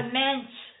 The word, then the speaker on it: mensch: A mensch. – Spell it for him, honey. – M-E-N-S-H.